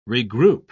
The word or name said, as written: regroup